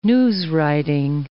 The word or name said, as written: newswriting